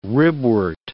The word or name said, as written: ribwort